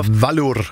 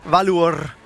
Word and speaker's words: Valur: Hvordan er det med, du udtaler det? – Valur. – Valur. Valur fra Reykjavik. Det er nemlig rigtigt.